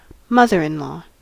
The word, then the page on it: mother-in-law: English